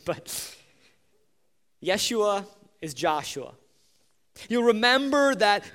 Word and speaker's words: Yeshua: The name Jesus is actually pronounced Yeshua in Hebrew, and it is the name Joshua. By the way,